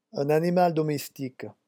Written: un animal

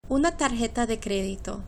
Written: una tarjeta de credito

